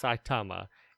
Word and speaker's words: Saitama: It's about a guy named, correct me if I'm saying this wrong, Satamana or Satama? – Saitama.